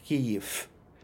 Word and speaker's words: Kyiv: And in languages like Russian and Polish, in the final word and final syllable position, V legitimately becomes F. So that's why Russians would say Kyiv, and so would Poles. But in Ukrainian, that interpretation is not available because F is not available.